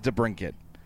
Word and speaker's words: DeBrincat: It is DeBrincat. – Yeah, that's what I was told that as well, and I've been trying to say DeBrincat, like, frequently, and I still occasionally elapse, and I'll say DeBrincat, but I've actually been getting better about it after much cajoling and prodding, so...